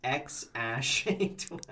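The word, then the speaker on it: X Æ A-12: Joey, he identified the second character as Ash, saying it was pronounced X-Ash A-12.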